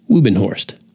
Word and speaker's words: Wubenhorst: So I think it's important that you know the doctor's name is Monique Wubenhorst, or it looks like Wubenhorst, but I believe it's pronounced Wubenhorst.